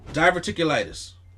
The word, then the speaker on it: diverticulitis: Diverticulitis.